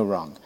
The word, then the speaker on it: wrong: Ela quis dizer aqui, não me entenda mal, não me leve a mal, don't get me wrong. Normalmente nós não pronunciamos esse G do final, wrong. O G quase não sai, ou não sai muitas das vezes.